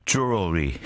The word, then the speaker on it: jewelry: I think Jewelry 이거 참 힘든데요. Jewelry, Jewelry. How about the British way? Jewelry. Jewelry라고 이렇게 발음을 하는 분들도 가끔 계시긴 한데, I guess the correct way is jewelry.